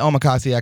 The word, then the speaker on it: omakase: I mean, omakase, and is that how you say it? – Yeah, omakase.